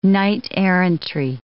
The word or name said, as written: knight-errantry